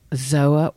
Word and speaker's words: Pzoa: Zoa Organics. – Ooh. – The P is silent. Yeah, P-Z-O-A.